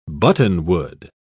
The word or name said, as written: buttonwood